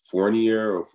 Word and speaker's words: Fournier: I can't never pronounce that man's name. – Fournier.